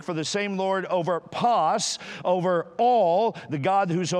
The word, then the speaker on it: pas: P-A-S would be the phonetic pronunciation of that, pas.